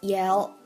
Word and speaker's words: yell: All he does is yell, yell, yell. Yell 的发音还记得吗？把舌头最前面的部分拧到你牙齿上排牙齿的后面。 Yell, yell.